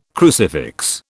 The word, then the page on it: crucifix: British English